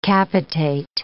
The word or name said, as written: capitate